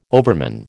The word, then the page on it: overman: American English